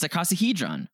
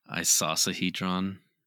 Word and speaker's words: icosahedron: What did I say? It's icosahedron. – Icosahedron. I think it's isosahedron. – Isosahedron. – Icosahedron. – Isosahedron. – It's icosahedron, yeah.